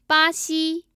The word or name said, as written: baxi